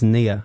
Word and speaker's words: Nia: Boys in Blue by Nina Archives is your final song for us tonight. – It's actually pronounced Nia, I'm afraid.